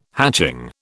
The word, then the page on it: hatching: British English